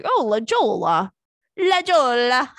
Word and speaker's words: La Jolla: I kind of have anxiety about pronouncing places because of that time that I said La Jolla instead of La Jolla.